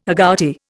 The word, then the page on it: agouti: Indian